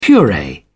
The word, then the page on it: puree: British English